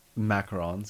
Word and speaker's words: macarons: Is it macaroons or macarons? – Macarons, because it's French.